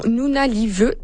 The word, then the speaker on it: Nunavut: Il y a une grosse vidéo aussi qui est surnommée Opération Nunavut. Est-ce que je l'ai bien prononcé? Je pense que oui. Hein? – Oui, okay. C'est parfait.